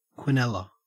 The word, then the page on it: quinella: Australian English